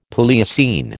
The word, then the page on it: Palaeocene: American English